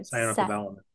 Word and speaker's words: cyanocobalamin: Cyanocobalamin.